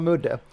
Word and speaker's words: Mudde: men låt oss kalla honom Mudde. Tudde och Mudde.